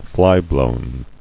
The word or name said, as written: Flyblown